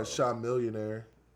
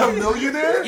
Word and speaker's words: Chamillionaire: Nah, Sean Millionaire. – That's definitely not how you pronounce his name. – I was like, "Who's the hell is that?" – That's definitely not how you pronounce that? His name. What is that, Chamillionaire? – Yeah.